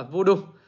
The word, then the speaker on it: VDU: hiện tượng nó gọi là voodoo voodoo VDU đọc là voodoo